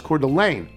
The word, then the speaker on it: Coeur d'Alene: That's pronounced Coeur d'Alene.